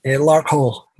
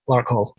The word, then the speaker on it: Larkhill: Okay, Larkhill, is that how we're pronouncing this town in south-east of Glasgow, is that right? – Larkhill, yeah, Larkhill.